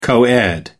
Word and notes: coed: American English

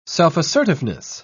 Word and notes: self-assertiveness: British English

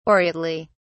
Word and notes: aureately: British English

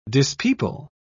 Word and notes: dispeople: British English

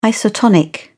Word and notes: isotonic: British English

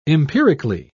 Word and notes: empirically: British English

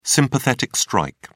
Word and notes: sympathetic strike: British English

